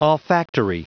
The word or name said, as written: olfactory